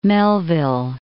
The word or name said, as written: Melville